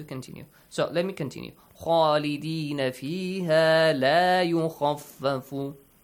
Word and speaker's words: خالدين: So, the first important rule here, خالدين فيها, the pronunciation of the letter خا is important since it's a heavy letter and it has an alif mad with it. So you gotta pronounce it with an ah sound.